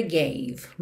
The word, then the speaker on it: gave: Gave more, sem pronunciar os E's, ok? Então, more, termina o som no R enrolado, da porta com a perna esquerda. Gave, termina o som no V.